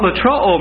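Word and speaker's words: lutroo: Spelled L-U-T-R-O, long O. And what latroo means is to release for a ransom.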